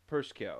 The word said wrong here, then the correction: Prochnow: Perchow. I can't pronounce it. – Almost there. Jürgen, right there. – Jürgen Prochnow. – Yeah, I buy that. – Fuck it. That sounds decent.